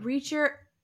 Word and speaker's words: Ritchson: Yeah, R-I-T-C-H. – Reacher? – Yeah, Reacher. But. – Spell it. – But Reacher, R-I-T-C-H-S-O-N. Because he's, I was imagining a guy...